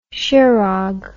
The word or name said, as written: shearhog